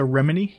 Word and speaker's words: Remini: Is that how you pronounce her last name? – Leah Remini, yes.